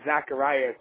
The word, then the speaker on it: Zachariah: It's funny, my real name is Zachariah, so it's spelled Z-A-C-H-A-R-I-A-H, but I go by Zach, and I use it with a K, but it don't matter to me.